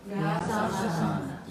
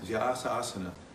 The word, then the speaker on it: Vyasasana: Vyasasana. – Vyasasana. So that's how it's actually pronounced. Many people say Vyasasana or any other kind of thing, but it's a Vyasasana.